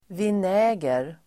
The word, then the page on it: vinäger: [vin'ä:ger]